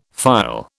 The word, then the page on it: phial: British English